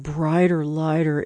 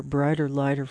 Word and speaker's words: Bryter Layter: That's from Nick's second album called Brighter Lighter. It's spelled kind of funny, B-R-Y-T-E-R-L-A-Y-T-E-R, Brighter Lighter from 1970.